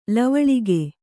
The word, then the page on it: ಲವಳಿಗೆ: kannada